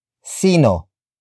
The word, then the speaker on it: sino: This time we learn about the Tagalog word for who, which is sino. Sino. Sino. Sino is spelled as S-I-N-O.